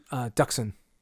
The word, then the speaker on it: Dxun: I think that's how it's said. D-X-U-N. – Oh, Duxin. – It's Duxin, really? – Yeah.